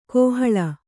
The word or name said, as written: ಕೋಹಾಳೆ